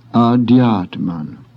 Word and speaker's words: adhyatman: The next word has an aspirated D, followed by a Y. Hence, most people have difficulty in pronouncing. Thus, adhyatman, the supreme self.